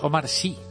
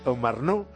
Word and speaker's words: Omar Sy: No, Omar Sy, Omar No. – No es Omar No, sino Omar Sy. – Omar Sy y Charlotte Gainsbourg,